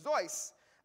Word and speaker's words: Seuss: Actually, his name is Theodore Zeus, like it's spelled Seuss, but being from German descent, he actually pronounced his middle name spelled like Seuss, rhyming with voice. He said it rhymes with voice, so it's Zeus, Theodor Zeus Geisel.